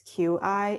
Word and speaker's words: Qi: And Qi is, well, they I think in the subtitles, they spelled it C-H-I, but technically it's Q-I in Chinese pinyin, but it's pronounced C-H-I, so Qi.